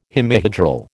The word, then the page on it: hemihedral: American English